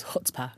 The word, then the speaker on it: chutzpah: One of my favorite words is also, I think, Yiddish, which is chutzpah. Is mm-hmm. that how you say it? – Chutzpah. Yeah, get the ch going.